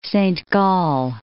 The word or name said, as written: Saint Gall